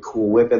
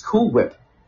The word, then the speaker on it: Cool Whip: It's Cool Whip. Yeah, Cool Whip. Why do you put so much emphasis on the H?